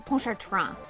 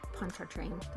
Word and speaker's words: Pontchartrain: Jerome de Pontchartrain how they would have said it, but you can say Pontchartrain. That's fine. – Pontchartrain. – Pontchartrain. – That's how they say it down in Louisiana.